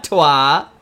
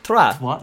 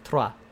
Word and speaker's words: trois: Roll your R's. Not trois. – Trois? How do I do it? – Trois. You have to roll your R. It has to like roll at the back of your throat, like trois.